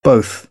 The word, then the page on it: both: British English; əʊ